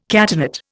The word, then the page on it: catenate: Indian